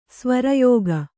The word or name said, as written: ಸ್ವರಯೋಗ